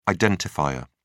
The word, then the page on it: identifier: British English